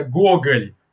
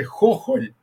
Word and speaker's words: Gogol: Já, já é que, assim, aqui a gente tem um problema sério, que a pronúncia em, em, russo já difere um pouquinho do que a gente usa, né? Seria Gogol, Gogol. A pronúncia ucraniana, então, aí já fica bem esquisito, que é Hohol, Hohol, né? Porque o G é, ucraniano tradicionalmente é um, é um, é tipo um H aspirado, né?